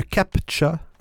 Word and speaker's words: CAPTCHA: Un CAPTCHA, on se prononce ça, C-A-P-T-C-H-A, un CAPTCHA,